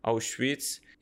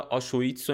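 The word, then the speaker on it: Auschwitz: آوشویتس یعنی یکی از ترسناکترین اردوگاه های تاریخ بشر میبرنش حالا آشویتس و اینا هم میگم ولی تلفظ درستش همون آوشویتسه این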